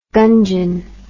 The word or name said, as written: gungeon